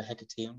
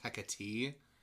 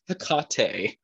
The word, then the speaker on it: Hecate: I think it's actually pronounced Hecate. – I thought it was Tecate. – Yeah, like the what is it? Is it the Mexican beer? The Tecate. Yes, and it's like Hecate.